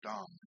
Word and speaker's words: dam: The syllable for a D grace note on low G is D-A-M, and it's pronounced dom. And a D grace note on low A is D-A-N, don.